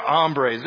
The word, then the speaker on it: hombre: I know I said it wrong. Hombre, not hombre. Hombre means hungry, I've been told. So they're not the hungry guys.